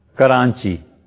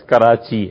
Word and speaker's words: کراچی: گھاس، ایک اور لفظ بتائیں، کرانچی، کرانچی، حالانکہ وہ لفظ کراچی ہے. اور آپ بتائیے کوئی لفظ جی. کوئی کوئی بندے بولتے ہیں کریچی. اچھا ہاں، تلفظ کا فرق ہے، بالکل بہت سے لوگ ایسے بولتے ہیں، لیکن اصل جو لفظ ہے وہ کراچی.